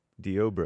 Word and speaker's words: Deobra: Deobra. – I'm told by Derek, my producer, it's pronounced Deobra.